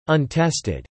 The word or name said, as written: untested